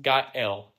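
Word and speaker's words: ga'el (גאל): So the first translation of the word redemption is a Hebrew word that is pronounced ga'el, and it means to redeemed, and is personified in the idea of a kinsman redeemer.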